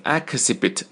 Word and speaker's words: exhibit: 那么我们顺便来说一下这个 exhibition 它的动词啊，就是把最后三个字母 i o n 去掉，注意发音，不要念成 exhibit， no，不是这样的，应该念成 exhibit， exhibit 重音在 x h i，因为 h 它不发音嘛，就是 x i 这里 exhibit， exhibit 啊，这么来念 exhibit 啊，叫展现展示。